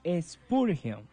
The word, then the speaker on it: Spurgeon: El pensamiento de Spurgeon.